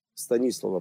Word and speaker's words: Станислава: Станислава, правильно говорить по-чешски, он чех, вот, который когда-то нам выдал, вот основал вчера еще раз, напоминаю, да, просто мне нравятся наши спикеры.